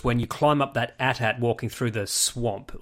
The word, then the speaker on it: AT-AT: I from George Lucas's mouth, it's pronounced AT-AT, so I'm going with it.